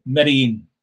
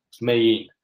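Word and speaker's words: Medellín: Medellin. – Medellin.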